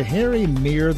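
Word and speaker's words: Harry Mehre: The book, and he's got it in front of me now, From Notre Dame to Georgia, Harry Meier the Legend. And that is the pronunciation. Go through that. That's how the man actually pronounced his name.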